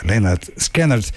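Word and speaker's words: Lynyrd Skynyrd: Ja, van een debuutalbum geproduceerd door niemand minder dan Al Kooper uit 1973, pronounced Leonard Skinner, draai ik het prachtige nummer op een heerlijke tekst, Poison Whiskey.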